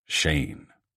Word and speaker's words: Shahin: Bringing me to Nicole Shane, spelled S-H-A-H-I-N, pronounced Shane.